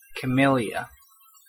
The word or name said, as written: camellia